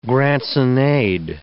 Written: grants-in-aid

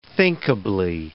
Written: thinkably

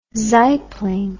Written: saibling

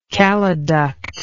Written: caliduct

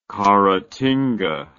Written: caratinga

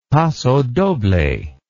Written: pasodoble

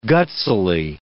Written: gutsily

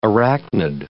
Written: arachnid

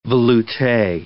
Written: veloute

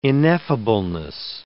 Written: ineffableness

